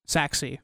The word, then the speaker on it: Sachse: what is it? Sashi? Is that how you say it? Sachse. – Sachse. Yeah, yeah.